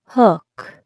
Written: hook

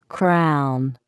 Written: crown

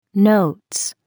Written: notes

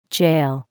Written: jail

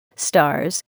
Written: stars

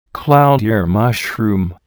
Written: cloud ear mushroom

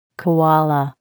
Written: koala

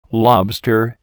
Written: lobster

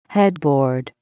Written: headboard

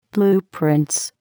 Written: blueprints

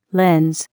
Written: lens

